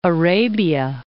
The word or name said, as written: Arabia